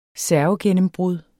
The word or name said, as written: servegennembrud